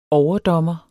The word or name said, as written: overdommer